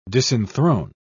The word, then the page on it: disenthrone: British English